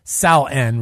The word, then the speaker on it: Samhain: It was the original Halloween alternative, and then it kind of came up against the pagan Samhain festival, which is pronounced Sal-en, really, if you're going to do it correctly, celebrated the final harvest, death, and the onset of winter for 3 days.